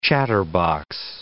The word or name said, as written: chatterbox